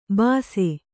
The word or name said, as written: ಬಸಿ